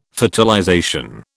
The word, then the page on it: fertilization: British English